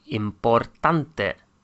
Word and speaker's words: importante: except there's an E at the end, which is pronounced. So important becomes importante.